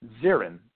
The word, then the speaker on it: Zirin: She goes to a David, I think it's pronounced Zirin, Z-I-R-I-N.